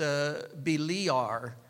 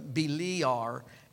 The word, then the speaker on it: Beliar: That, you know, and the real pronunciation is Beliar. And Beliar, and the real actual spelling is B-E-L-A-I-A-R, Beliar, Beliar. Lord, and here's the definition, the Lord of the forest, the Lord of the forest, a name for Satan.